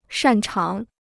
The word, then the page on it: 擅长: shàn cháng